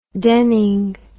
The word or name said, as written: denning